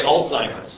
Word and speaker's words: Alzheimer's: With these stem cells, we want to help work on Alzheimer's. – Okay, Alzheimer's. There we go. That was the correct pronunciation.